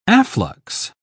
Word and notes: afflux: British English

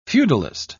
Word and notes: feudalist: British English

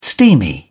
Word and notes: steamy: British English

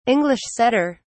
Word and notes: English setter: British English

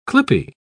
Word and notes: clippie: British English

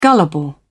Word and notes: gullible: British English

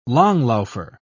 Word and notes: langlaufer: British English

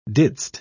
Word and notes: didst: British English

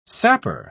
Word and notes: sapper: British English

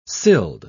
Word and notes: sild: British English

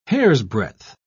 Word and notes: hairsbreadth: British English

